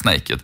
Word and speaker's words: NA-KD: Eh, det skrivs ju NA-KD, men det uttalas Naked.